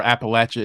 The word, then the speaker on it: Appalachia: And the way that I remember Appalachia is because, as someone said, like, the mnemonic device, say it wrong, they'll throw an apple at you.